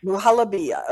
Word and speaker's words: muhalabia: Pronounce that word for me again, please. The one that I mispronounced, muhalabia. How do you say it? – Muhalabia.